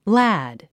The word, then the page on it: lad: lǽd; ラド